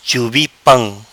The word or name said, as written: Chubi pam